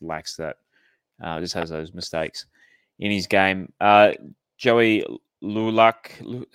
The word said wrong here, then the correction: Lulash: how do I say his name? – Lolash. I got to get some Lebo. – It's a Lebanese name. Okay. – It's a Lebanese Lulash.